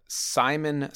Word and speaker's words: Psymon: They are called Simon Spines. Simon is spelled P-S-Y-M-O-N.